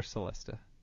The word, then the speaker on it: celesta: Do you pronounce it celesta or celesta? – I pronounce it celesta. – What was that? Celesta? – Celesta. – Okay. – Like C-H, ch.